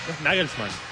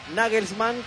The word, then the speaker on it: Nagelsmann: Es Nagelsmann.